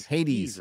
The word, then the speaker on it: Hades: Tell MauLer to play Hades and call him a cabbage. – Play what? – Hades? H-A-D-E-S? Hades.